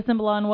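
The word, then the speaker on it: cimbalom: Is that how you say it? Because I don't know. Cymbalon? – That was quite good, yeah.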